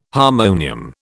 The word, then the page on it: harmonium: British English